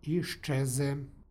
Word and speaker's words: iščeze: stihu, u primjeru iščeze, dolazi do jednačenja suglasnika. Dakle, z prilikom izgovora prelazi u š, iščeze.